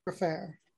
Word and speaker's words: Gfrorer: G-F-R-O-R-E-R, Graffaire. She told me that I pronounce her name better than anyone else.